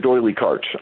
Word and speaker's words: D'Oyly Carte: Yes, Richard D'Oyly Carte.